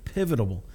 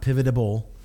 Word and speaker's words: pivotal: Pivotal. Am I saying that right? Pivotal. Not pivotable, pivotal, got it.